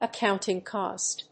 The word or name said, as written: accounting cost